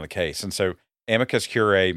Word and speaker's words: curiae: We now have a motion from the ACLU of South Carolina, and they want to file a brief of amicus curiae. Is that how you say it? – Curiae.